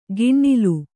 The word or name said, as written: ಗಿಣ್ಣಿಲು